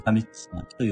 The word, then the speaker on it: 上巻: えー、上巻と書いて神つまきと読むそうですね。